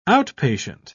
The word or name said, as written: outpatient